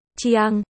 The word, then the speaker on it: Khương: Chú thích chút cho cái tiêu đề nà họ của bà Ngôn là Khương mang nghĩa họ Khương và củ gừng cũng là từ này luôn. Chiang, còn họ Úc, yêu, thì lại có phát âm là uy, gần âm nhưng khác thanh điệu với chữ ngư con cá.